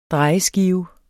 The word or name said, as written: drejeskive